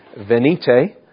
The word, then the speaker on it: Venite: Psalm 95 is, I think the pronunciation is Venite, which is the Latin apparently for O come.